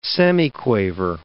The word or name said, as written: semiquaver